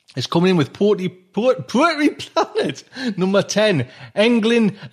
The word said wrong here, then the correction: Elgin: and the Dwarf Star Awards. Is that how showcase? Is that how you pronounce it, Diane? Elgin and the Dwarf Star Awards showcase.